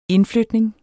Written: indflytning

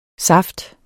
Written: saft

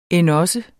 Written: endogså

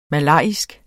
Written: malajisk

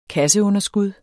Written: kasseunderskud

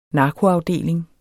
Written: narkoafdeling

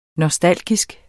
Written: nostalgisk

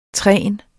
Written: trines

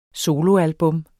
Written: solo

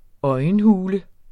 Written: øjenhule

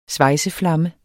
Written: svejseflamme

